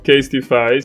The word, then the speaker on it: Casetify: Ma non è finita qui, perché un'altra collaborazione è quella con Casetify, che è un'azienda di accessori tecnologici che vende cover di iPhone e anche caricabatterie wireless, le AirPods. – Sì, la conosco, Casetify. – Grazie per avermi corretto, perché io non sapevo benissimo okay.